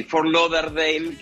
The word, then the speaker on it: Fort Lauderdale: Sí, Fort Lauderdale, que a veces no es tan fácil decirlo, pero esa es la pronunciación correcta.